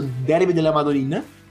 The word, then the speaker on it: Derby della Madonnina: Derby della Madonnina. – Está boa, está boa a pronúncia.